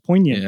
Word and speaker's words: poignant: Yeah, poignant. Yeah. There we go. I always try to pronounce the G.